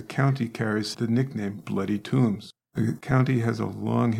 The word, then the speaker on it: Toombs: So Santa Claus is located in Toombs County. That's T-O-O-M-B-S, not T-O-M-B-S, although okay. – It's pronounced the same.